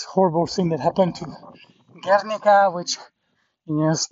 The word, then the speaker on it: Guernica: So I was saying it wrong. I was saying Guernica or Ganerki or whatever, or Guernica. It's actually Guernica, which is a famous painting of Picasso.